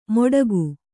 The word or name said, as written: ಮೊಟಕು